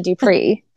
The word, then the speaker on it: DuPrez: And it's DuPrez, but with a silent Z. – Yes. Yes. Just like the terrible movie that's out there, You, Me, and DuPrez. Do not recommend. But yes, that is how you say our last name. The Z is silent. It's French.